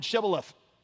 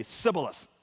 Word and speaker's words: Shibboleth: And when they said no, they would say to him, then say the word Shibboleth. And he would say, Sibboleth. Because he could not pronounce it right.